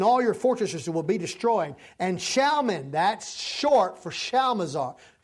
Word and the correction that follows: Shalmaneser: Shalmaneser, I'm sorry, the king of Assyria. I don't know why I say that wrong every time.